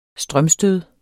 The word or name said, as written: strømstød